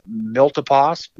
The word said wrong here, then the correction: Milpitas: Milpitas, something Milpitas. like that.